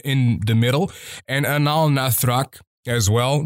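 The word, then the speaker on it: Anaal Nathrakh: but another band is Anal Nathrak. Yes, it's pronounced Anal. I think it's Anal Nathrak.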